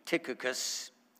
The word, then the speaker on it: Tychicus: And I mentioned that the CH there is pronounced K, Tychicus,